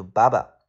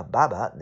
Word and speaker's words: barber: 英式发音和美式发音的嗯区别很大，英式发音读 barber，有点像爸爸的感觉啊，barber。美式发音叫 barber 啊。好，比如说以前啊，有一个理发师啊，叫 Michael。 There used to be a barber named Michael。